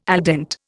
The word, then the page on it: al dente: Indian